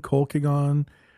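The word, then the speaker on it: Colkegan: It's from Colkegon, Santa Fe Spirits. And this is a single malt whiskey aged in American white oak barrels. – Mm, it rhymes with vegan.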